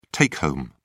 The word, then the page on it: take-home: British English